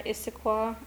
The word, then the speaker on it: Issaquah: And it says here he, like, handcuffed her and drove her to Issaquah. – Issaquah? – I think I'm saying it right. Issaquah? – That sounds right.